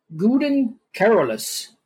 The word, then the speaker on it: Gouden Carolus: Gouden Carolus. Gouden Carolus, like the Gouda cheese, but with an N on it.